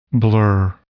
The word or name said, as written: blur